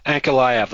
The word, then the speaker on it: Ankalaev: Ankalaev, that's what I was trying to say before, guys. Dan reminded me, oh yeah, that's how his name's pronounced.